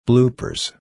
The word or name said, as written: bloopers